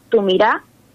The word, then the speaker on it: Tu Mirá: Si querés buscar Bicha, tu mirá, así con acento en la A, tu mirá.